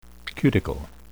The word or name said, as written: cuticle